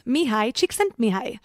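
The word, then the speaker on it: Mihaly Csikszentmihalyi: I'm going to link to it in the show notes because the author is really hard to pronounce and the spelling is completely different than the pronunciation. It's Mihai Csikszentmihalyi. Yep, but it doesn't look like it's spelled like that whatsoever.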